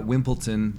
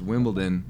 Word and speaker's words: Wimbledon: Not Wimpleton. That's not how it's said. It's Wimbledon.